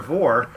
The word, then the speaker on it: Vore: I mean, he's probably a Vore guy. If you haven't looked up Vore, don't do that. – But it's spelled V-O-R-E, and it's like carnivore.